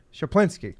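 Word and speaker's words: Schuplinski: Now it'll be, you know, they brought in Jerry, the new, what did I pronounce it? Shaplinski. Okay, that sounds right. – Shaplinski.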